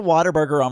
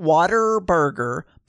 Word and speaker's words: Whataburger: So we went to Whataburger on Friday night. Not Whataburger, but What a Burger. What a Burger. But it's spelled, it's pronounced Whataburger. Not water, Wata. No R. Scotty Battler.